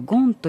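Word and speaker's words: Gand: ですが、ルイエってね、フランスで大変を過ごしているのでフランス人と思われがちなんですが、実はベルギー出身の作曲家でね、通称ジャン・バティスト・ルイエ・ド・ガンと呼ばれていて、ド・ガンっていうのはベルギーのゲントという町のフランス語読みで、どちらかというとゴンという発音が正しいんですけど、ゲントのルイエという意味なんですね。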